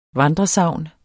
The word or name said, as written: vandresagn